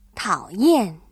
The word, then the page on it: 讨厌: tǎoyàn